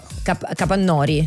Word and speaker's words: Capannori: Io non ho ancora capito come si Capannori. – Capannori.